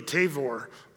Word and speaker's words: Tavor: And in Israel, they pronounce it with a V, Tabor.